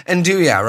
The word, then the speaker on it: andouille: Learn it once. Andouille. That's what it's called. That's what it's pronounced. Andouille, right? – Is that the sausage thing? The Andouille? Nuja. It's like a sausage thing, isn't it? – It's Andouille. Pronounce it fucking properly.